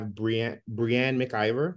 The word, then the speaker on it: Breanne Mc Ivor: Today we have Breanne Mc Ivor.